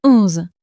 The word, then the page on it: onze: French